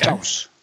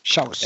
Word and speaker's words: Xaus: Pronunciation? Ruben Xaus. – Xaus. – Shouts, Oh, okay. Shouse. – Shouse. Yep.